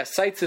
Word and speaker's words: Seitz: Yeah, Seitz is spelled S-E-I-T-Z. – Still, he's got you in his sights.